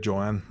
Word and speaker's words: Joan: No sé si le suena este catalán Juan Pujol García. Eh, Pujol, creo que se pronuncia Pujol. – Pujol, y el nombre Joan, más bien.